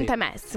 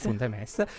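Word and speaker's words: Punt e Mes: Qua chiedo la pronuncia alla piemontese. – Punte Mes. – Punte Mes.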